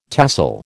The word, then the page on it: tahsil: American English